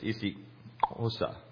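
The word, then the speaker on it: isiXhosa: They have a lot of clicks in their language, so it's Isi Osa. It's the C sound is actually spelled XH, and it's a Osa.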